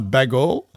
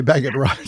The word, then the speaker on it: Bagot: I think it's Bagot Road, unless it's a French pronunciation. Is that right? Bagot Road, Subi Echo? – Yeah. – Okay. – Yep, that's it. – It's not Bagall. Okay, Bagot Road, Subi Echo.